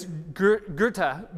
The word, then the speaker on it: Goethe: And then about 200 years later, a man named Johann Wolfgang von Goethe, which I for years said Goethe or Goethe, like how do you say this dude's name? But it's Goethe, became sort of the German Shakespeare.